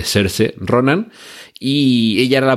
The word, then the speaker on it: Saoirse: Hannah, una película protagonizada por, vamos de nuevo con las pronunciaciones, se escribe Saoirse Ronan, pero como ella misma en algún programa de televisión ha explicado, se pronuncia algo así como serse, ¿vale? Serse Ronan.